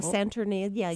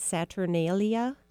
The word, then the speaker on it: Saturnalia: Saturnia. – Yes. – Because that was a huge festival. – Oh, Saturnalia, yes.